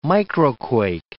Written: microquake

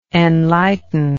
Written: enlighteningly

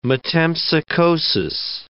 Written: metempsychosis

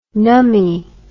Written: nummy

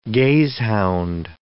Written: gazehound

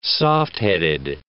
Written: softheaded

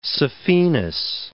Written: saphenous

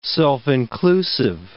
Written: self-inclusive

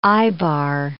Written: eyebar

